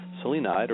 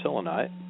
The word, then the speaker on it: selenite: Tonight's stone, by the way, is selenite or selenite. I've heard it pronounced both ways, and sometimes pronounce it both ways at the same time.